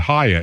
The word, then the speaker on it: HIA: Speaking of NOAA, you know what Haya is? Haya. – Mm-hmm. – What is it? Haya. – Like hi, but with an extra syllable in it.